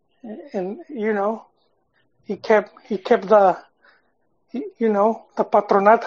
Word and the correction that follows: paternidad: how you say it? – It's paternidad. Su papá. – Yeah, paternidad. There you go.